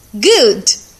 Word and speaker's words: good: Good, que significa bom, good, G-O-O-D, e book, B-O-O-K. Sempre que eu tiver dois O's, O-O, eu pronuncio como U. Good, book, ok?